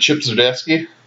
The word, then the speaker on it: Zdarsky: And I don't know how long he's been around, but that Chip Zdarsky, did I say that right? – Yes.